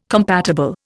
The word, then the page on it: compatible: Indian